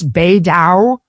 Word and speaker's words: Beidou: I believe you pronounce this Beidou.